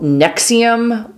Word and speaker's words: NXIVM: The group is called Nexium, like the, I think it's an anti-indigestion medication or yes. something, mm-hmm. but it's not spelled that way. It's spelled without any vowels. – Very obnoxiously, N-X-I-V-M.